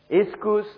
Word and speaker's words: ischus: For those of you who like the spellings, dunamis, most Christians know, is D-U-N-A-M-I-S. Iskus is spelled as I-S-C-H-U-S, Romanized. And kratos is Romanized as K-R-A-T-O-S, kratos.